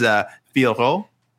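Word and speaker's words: Fiorot: So they are just like, just go with Fior. It's fine. This is acceptable. We just don't want people tripping over it. But it is Fioro. People, if you can, if you can roll your R's a little bit too, it'd be nice.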